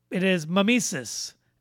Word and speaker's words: mimesis: It is mimesis. M-I-M-E-S-I-S.